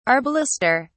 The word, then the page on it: arbalester: British English